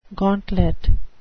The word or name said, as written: gauntlet